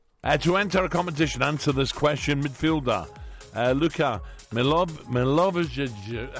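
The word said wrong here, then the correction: Milivojević: How do you say that? – Milivojevic. – Milivojevic.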